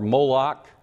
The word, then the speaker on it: Moloch: Molech or Moloch, either pronunciation is correct, and Chemosh and Molech.